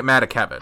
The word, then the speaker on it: Matakevich: How do you say his last name? Matakevich? – I'm pronouncing it Matakevich.